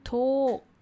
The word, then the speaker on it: talk: Engaging in small talk. You know what, guys? L is silent when we say talk. Talking, L is silent. So it's just talk. It's a long O, talk.